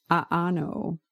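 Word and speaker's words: a'ano (ʻaʻano): But I believe that it's pronounced a'ano. It's spelled A-A-N-O, and it's got the okina between the two A's.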